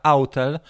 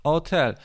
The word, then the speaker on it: Autel: Dużo osób w Polsce i też YouTuberów używa określenia autel e, i ja się długo wahałem, ale autel jest to czytane po polsku, natomiast nawet John McBride, McBride wymawia tak samo, jak ja wymawiałem od początku, hotel.